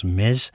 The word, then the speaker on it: Metz: Pierné werd geboren in Metz, je moet dat geloof ik uitspreken als Metz,